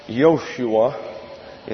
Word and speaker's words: Yoshua: The Old Testament name, Joshua, it's pronounced Yoshua in the Hebrew, means Jehovah is salvation.